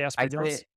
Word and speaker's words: Aspergillus: You say Aspergillus? – I say Aspergillus with a soft G.